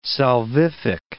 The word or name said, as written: salvific